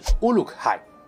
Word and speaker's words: Uruk-hai: 强兽人在中土世界中黑暗语的发音是 Uruk-hai， Uruk 的意思是欧克，也就是一般俗称的半兽人。